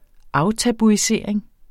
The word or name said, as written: aftabuisering